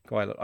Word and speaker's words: Gwaelod: Oh no, G-W-A-E-L-O-D. How would you pronounce that one? – G-W-A, go on. – E-L-O-D. – E-L-O-D. Gwelod? – Gwelod. Okay. And I'm not going to edit that out, everybody.